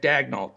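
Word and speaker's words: Daigneault: but he's the the gentleman that we can never pronounce his name that took over at Oklahoma City. Dagnall. – And in my opinion, yeah, yeah Dag, Dagnall, that's it.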